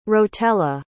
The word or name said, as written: Rotella